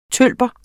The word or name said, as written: tølper